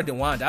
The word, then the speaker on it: DeWanda: She doesn't even if you go watch that movie, and every scene that Kevin Hart and Deanna Wise are in together, is it Deanna or DeWanda? I think I'm saying her name right. I think it's DeWanda Wise. I'm sorry, I'm saying her name. I've been saying Deanna.